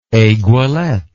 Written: aiguilletted

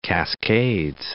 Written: North Cascades National Park

